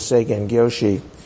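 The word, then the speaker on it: Seigen Gyōshi: His name is Seigen Gyoshi. That's the pronunciation in Japanese.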